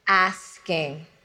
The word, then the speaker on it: asking: Think about breaking the word into ass and king, and then put the two together to say it correctly. Asking.